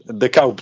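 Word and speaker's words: De Kuip: De Kaup, según se pronuncia en holandés.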